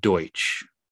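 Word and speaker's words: Deutch: Mabel works at the store, played by Zoe Dutch. – It's actually pronounced Deutsch, just so you know.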